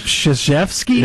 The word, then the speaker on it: Krzyzewski: He pronounces that crap Krzyzewski? – Yes. – Yeah, that's weird. – Okay, well, he's in the wrong, not me. – So you think his family's in the wrong for spelling it that way, pronouncing it? – Yeah, they're pronouncing it incorrectly. – That is crazy that you get Krzyzewski out of that.